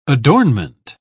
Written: adornment

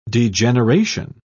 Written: degeneration

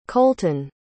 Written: kolten

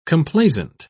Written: complaisant

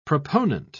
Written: proponent

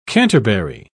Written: canterbury

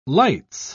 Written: lights

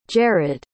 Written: jarod

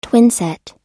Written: twinset

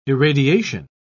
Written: irradiation

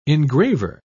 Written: engraver

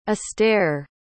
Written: astaire

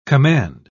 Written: command

